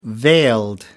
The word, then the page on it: veiled: American English